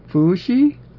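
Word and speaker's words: Fu Shi (伏羲): Oh, it's Fu Shi? Okay, yeah. Fu Shi. That's the pronunciation.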